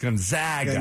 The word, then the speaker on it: Gonzaga: Yes, it's Gonzaga.